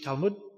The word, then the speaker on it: Talmud: Those of you that know about the Jewish Talmud, or Talmud as it's pronounced, it was some writings which came about.